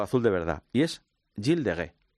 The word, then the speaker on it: Gilles de Rais: Es que claro, los franceses ya sabes que escriben las palabras y luego las pronuncian. – Sí, porque en verdad es Gilles de Rais. – Gilles de Rais, claro.